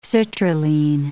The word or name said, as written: citrulline